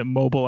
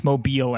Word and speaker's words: Mobile: Well, first point, I just want to say, how dare you pronounce it Mobile, Alabama? It's Mobile, Alabama.